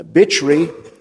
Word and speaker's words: Bichri: Bichri is probably the best way to pronounce it. Bich, where there's a CH and it's underlined, it's Ch.